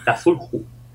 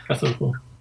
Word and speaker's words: Cthulhu: Cthulhu. – Cthulhu. Cthulhu.